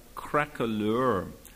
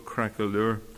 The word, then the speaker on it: craquelure: It's about a very ugly word, this poem, crackalure, which is spelt, I'm sure you all know the word, C-R-A-Q-U-E-L-U-R-E. It's the word applied to paint when it cracks on an old painting long after it's dried. It's called crackalure.